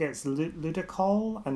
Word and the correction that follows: Ludicolo: Ludicolo, Ludicolo.